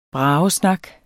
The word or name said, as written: bragesnak